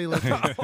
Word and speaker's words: Latinx: I did say Latinx.